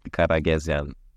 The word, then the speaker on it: Karagezian: So my last name is Armenian, which is quite unusual, I guess, but it's Karagezian.